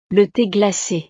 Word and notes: le the glace: frances

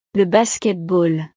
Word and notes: le basketball: frances

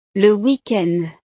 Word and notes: le weekend: frances

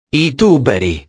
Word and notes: i tuberi: italiano

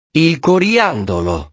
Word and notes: il coriandolo: italiano

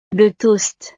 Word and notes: le toast: frances

